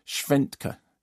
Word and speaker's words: Schwentke: Schwentke. S-C-H-W-E-N-T-K-E, okay.